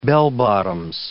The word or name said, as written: bell-bottoms